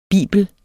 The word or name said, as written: bibel